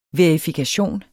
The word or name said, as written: verifikation